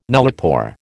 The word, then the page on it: nullipore: American English